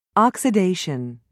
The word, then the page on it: oxidation: British English